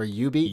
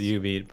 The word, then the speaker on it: jubeat: I think we talked about Jubeat. Or U-Beat. – U-Beat. There we go. Yeah, you said something much worse. – Oh, my goodness. Oh, gosh. No, I didn't mean that. I didn't mean it's spelled J. – It's pronounced U-Beat.